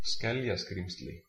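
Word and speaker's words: Skeljaskrímsli: Oh, wait. I might have found how to say it. How to say it in Icelandic. Yes. No. Just tell me how to say it. – Skeljaskrimsli.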